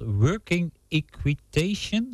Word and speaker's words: working equitation: Working equitation, spreek ik het goed uit zo? – Ja, spreek je goed uit, klopt helemaal.